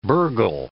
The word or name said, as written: burghal